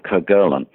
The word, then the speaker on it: Kerguelen: It's actually written about, I'm not going to attempt the French pronunciation, so I'll give you an anglicized version of the name. But it's actually about a small outcrop of rocks called Kerguelen, which is in the South Indian Ocean, around about the 50th parallel, I think.